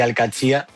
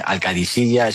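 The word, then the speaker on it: Al-Qadsiah: Bueno, se suele decir Al-Katsia, pero también hay gente que dice Al-Kadisiya, es que es difícil, ¿eh? Ah. No es fácil, pero Al-Katsia es lo más fácil.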